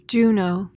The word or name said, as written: juno